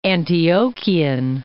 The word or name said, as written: Antiochian